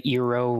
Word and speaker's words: eero: but I've loved the Eero routers, or it might be pronounced Eero, but it's E-E-R-O.